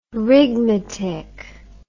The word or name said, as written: rigmatick